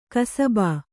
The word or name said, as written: ಕಸಬ